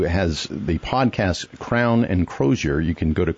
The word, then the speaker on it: Crozier: We're going to talk about the website Crown and Crozier. Did I say that right? – Yeah, his podcast. – Podcast. It comes with the website, too. Right. – Crozier. – Crozier. I knew it.